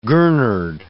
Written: gurnard

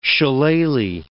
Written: shillalah

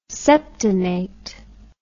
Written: septenate

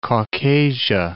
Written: Caucasia

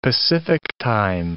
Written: Pacific time

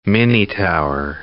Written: minitower

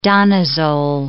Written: danazol

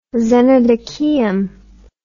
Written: xenodocheum

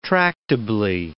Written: tractably

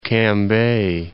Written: Cambay

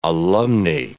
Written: alumnae

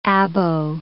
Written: abo

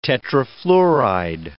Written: tetrafluoride

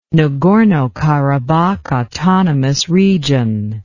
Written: nagorno karabakh autonomous region

